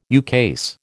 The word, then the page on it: ukase: American English